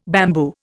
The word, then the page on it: bamboo: Indian